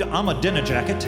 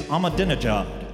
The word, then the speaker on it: Ahmadinejad: Incumbent President Mahmoud Ahmadinejad. Wait, I'm being told the correct pronunciation is Ahmadinejad.